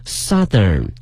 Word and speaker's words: southern: 更重要一点大家一定要记住了，南方这个词呢它是一个大口型的这个 south，嗷这个音 south，但是变成了南方的呢它的发音发生了变化，变成了 southern， southern。